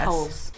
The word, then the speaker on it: Peebles: And his name is John Peebles. Or Peebles. – Pebbles. I don't know. – Peebles? – P-E-E-B-L-E-S. – Peebles. – Peebles. – Peebles. How did you spell it? – P-E-E-B-L-E-S.